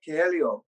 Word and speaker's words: Coelho: I can never say his last name properly. Kelly. I can spell it, but I cannot. – Paulio Coelho. – Yes, that one.